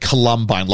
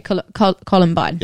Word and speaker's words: Columbine: You went Columbine, like Columbo. – Okay, Columbine. – Yeah. Okay. It's a hard call, not a color. – Okay. – Yeah. So the syllable breaks after the call.